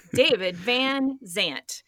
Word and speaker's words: Van Zandt: As David Vance said, I can't say his name, David Van Zandt.